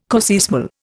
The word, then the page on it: coseismal: Indian